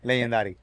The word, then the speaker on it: Legendaddy: Eh, la pronunciación es como en inglés, pero cuando tú ves el nombre, uno es Legend y el nombre mío y es... Legendary. Legendary.